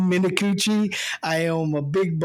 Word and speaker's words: Minicucci: He writes, "Guys, his name is Ben Minicucci. Pronounced Minicucci.